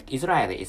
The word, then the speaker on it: Israeli: and I mistakenly pronounced is Israeli. And Israeli is some wrong pronunciation, and Israeli is the correct pronunciation for English.